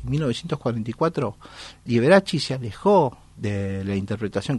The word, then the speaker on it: Liberace: Agregó el candelabro, famosísimo candelabro, sobre el piano como un accesorio de firma y adoptó Liberace como su nombre artístico, señalando en sus comunicados de prensa que debía pronunciarse así, Liberace.